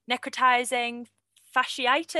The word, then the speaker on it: necrotizing fasciitis: I remember we had there was a case of, oh, I can never say this right, necrotizing fasciitis. Is that how you say right.